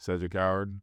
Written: Cedric Coward

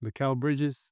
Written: Mikal Bridges

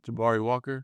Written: Jabari Walker

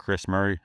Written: Kris Murray

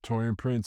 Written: Taurean Prince

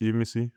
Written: Yves Missi

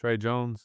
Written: Tre Jones

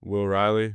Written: Will Riley

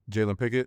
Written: Jalen Pickett

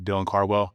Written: Dylan Cardwell

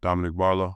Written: Dominick Barlow

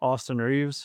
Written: Austin Reaves

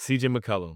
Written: CJ McCollum

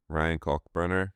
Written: Ryan Kalkbrenner

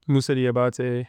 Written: Moussa Diabate

